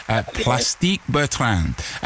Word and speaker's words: Plastique Bertrand: That's Plastic Bertrand. – Let's find out. Well done, Terry. Congratulations. And you get a half a bonus point as well for the pronunciation. Plastique Bertrand.